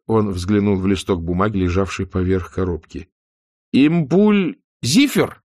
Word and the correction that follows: Импульсифер: «Импульсифер», — поправил его Ньют. Ударение на последний слог.